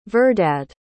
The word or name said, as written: verdad